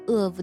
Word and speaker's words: of: 在我们读 of 的时候呢，只是读了一个 a 的音，并且和 absence 连读在一起了， v 并没有读出来。这呢就是一个关于 of 的读音规则，当它后面接辅音的时候呢， v 呢一般是只做口型不发声，读得快的时候呢， v 几乎是听不出来的。